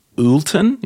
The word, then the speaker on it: Oulton: So for everyone, if you're still at your thingy, just Coco Republic, or it's the Timothy Oulton, is that how you pronounce it? O-U-L-T-O-N? – Yeah.